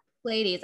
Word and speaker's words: Pleiades: Like it's not like, you know, and it's, I don't know if, you know, it's the Pleiades. Is that how you pronounce Pleiades? – It? – Yeah. – Pleiades. Okay. So it says double mission, channeling and uplifting humanity.